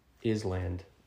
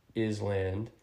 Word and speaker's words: island: quote-unquote, correctly pronounce the word island, or island as some might know it. I was reading, I was in, I want to say, first grade in class, and I was the one chosen to read out loud, and came across this word, and I pronounced it as it's spelled, island, and the teacher stopped me and corrected me, and I was horrified.